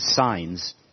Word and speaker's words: Sines: Anyway, he has a sign company, and his name is Signs. But now, John, this one's for you. This one's for you. Before he ran the sign company, he was a mathematics teacher, and his name is spelled S-I-N-E-S.